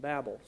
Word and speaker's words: Babel: Perhaps some of you, when I said Babel, have pronounced it Babel. But the Hebrew reading actually is Babel.